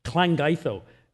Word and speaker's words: Llangeitho: Llangeitho. It's spelt L-L-A-N-G-E-I-T-H-O. But it's when you get two L's together, it's a close sound. Llangeitho.